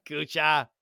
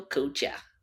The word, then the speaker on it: Couture: like who immigrate here and they just roll with a different pronunciation of their name because they get tired of saying how it like would actually be pronounced. So like okay, yeah, like, that's Kucha. – It. Yeah, Kucha. – Kucha. So my grandma says it, Daniel Kucha.